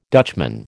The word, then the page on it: Dutchman: American English